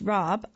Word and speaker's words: rabe: Rob, pronounced Rob, R-A-B-E is pronounced Rob, is related more closely to turnips than broccoli, but in season is broccoli Rob.